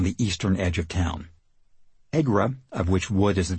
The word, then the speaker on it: ECGRA: This stands for Erie County Gaming Revenue Authority, and it is usually pronounced as a word, EGRA.